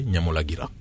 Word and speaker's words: Nyamulagira: Alors on prononce Nyiragongo et Nyamulagira.